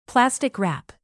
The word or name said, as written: plastic wrap